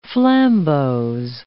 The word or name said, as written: flambeaux